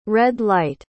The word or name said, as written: red-light